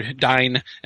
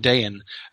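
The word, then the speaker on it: Dáin: So it's not Thrain and Dane or Dine and Thrine. It's Thrain and Dayan.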